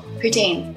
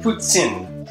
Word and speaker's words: poutine: How do I say the French fries with gravy and the cheese on top? – Poutine. – Let's say it correctly. – Platon. – I'm close. – Platon? – Poutine. – Poutine. – So if you guys ever go to Quebec, you have to say poutine.